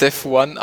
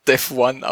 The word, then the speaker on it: Devuan: Und so gibt es auch ein paar Leute, die Debian gerne ohne Systemd hätten und haben jetzt da quasi neues Debian Devuyan. – Es wird Devuan ausgesprochen, habe ich vorher gerade gelesen.